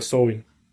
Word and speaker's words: Samhain: Se escribe Samhain, pero se pronuncia Samhain, la cual se celebraba el 1 de noviembre, el 1 de noviembre de los calendarios actuales.